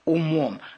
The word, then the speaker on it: умом: Это ангел-хранитель, переставший жить умом с ударением на «у», спрашивал, все ли у нас благополучно.